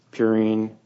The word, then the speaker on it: purine: He's saying how the base, can be purine and a pyrimidine.